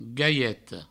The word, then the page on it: galheta: gaieto